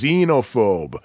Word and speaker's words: Xenophobe: By the way, it is pronounced Xenophobe. – Xenophobe. – Don't believe me? Go back to episode 24 and listen to what Brian Cullen said. He said Xenophobe.